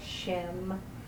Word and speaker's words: Shem: And on the back of the lifting is how to phonetically pronounce each name of God. Like Shem, S-H-E-M, Fer, F-E-R, Osh. Shem, Fer, Osh.